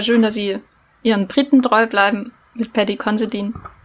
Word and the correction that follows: Considine: Considine, ach, schon wieder falsch ausgesprochen. Paddy Considine wird er ausgesprochen.